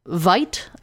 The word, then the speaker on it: Veidt: Finger produced an image of actor Conrad Veidt, I think is how you say his name, V-E-I-D-T,